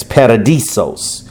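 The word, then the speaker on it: paradeisos: paradisos.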